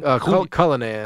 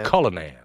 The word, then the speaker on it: Cullinan: The Royce, Cullinan? – It's the Cullinan. – Cullinan. – Yeah, Cullinan.